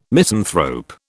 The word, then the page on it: misanthrope: British English